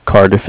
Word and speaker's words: Cardiff: The Cardiff is spelled C-A-R-D as in dog, I-F-F, like Foxtrot.